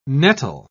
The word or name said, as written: nettle